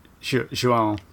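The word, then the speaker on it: João: and it's Joao have I pronounced that correct? J-O-A-O? – Joao, yeah. – Joao, thank you. – Yeah, when there's a wiggly thing over the top, it's an imaginary N that you have to kind of...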